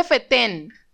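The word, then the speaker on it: fetén: No dijiste FETEM. – FETEN. – Esto está grabado. Sabes que lo puedo echar para atrás y lo puedo comprobar. – Maldita sea, FETEN. FETEN con acento en la segunda E.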